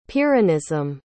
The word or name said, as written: pyrrhonism